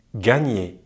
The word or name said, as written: gagner